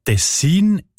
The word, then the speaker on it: Tessin: Ja, tatsächlich variiert die Betonung von dem Kantonsnamen, wo sich übrigens vom gleichnamigen Fluss ableitet, von Region zu Region oder sogar von Person zu Person. Die Variante Tessin ist häufiger.